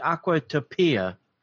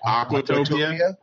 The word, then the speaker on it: Aquatopia: Like Aquatopia? – Aquatopia, yeah. – You put the emphasis on the wrong syllable.